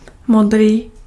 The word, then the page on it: modrý: [mɔdriː]